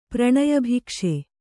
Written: ಪ್ರಣಯಭಿಕ್ಷೆ

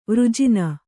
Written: ವರ್ಜನೆ

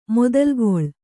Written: ಮೊದಲುಗೊಳ್

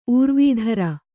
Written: ಊರ್ವೀಧರ

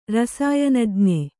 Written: ರಸಾಯನಜ್ಞೆ

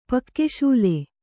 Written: ಪಾಕಶಾಲೆ

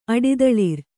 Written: ಅಡಿದಳಿರ್

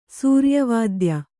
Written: ಸೂರ್ಯವಾದ್ಯ

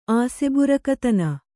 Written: ಆಸೆಬುರುಕತನ

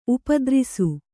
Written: ಉಪದ್ರಿಸು

